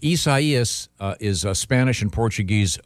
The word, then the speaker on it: Isaias: Just to make it's like Osiasis. – That's very, very close. It's Hurricane Isaias. Isaias.